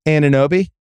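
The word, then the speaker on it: Anunoby: Ananobi? – Best one. You got it right.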